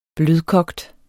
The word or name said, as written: blødkogt